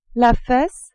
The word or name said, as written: fesse